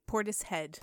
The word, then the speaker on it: Portishead: Portishead.